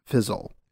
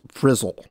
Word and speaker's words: Frizzle: And Elizabeth Banks is going to be producing it and starring it as Miss Fizzle. – Yeah. – Frizzle.